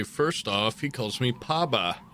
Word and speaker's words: Paba: P-A-B-A. – Phonetical? – Seem more P-O-B-A, but... – Poba. – Yeah, in this world, things are pronounced how I want them to be.